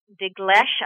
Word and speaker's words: Dinklage: I believe that the Peter Dinklage, I'm not sure if I'm pronouncing his last name correctly.